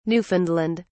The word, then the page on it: Newfoundland: British English